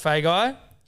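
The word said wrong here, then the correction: Feagai: F-E-A-G-A-I. – Yeah, it's Fine pronounced. – Oh. – Not fun guy.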